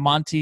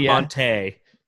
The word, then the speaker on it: Monty: Monty, yeah, Monty. – Monty, well, yeah. Monty, it's not Monte.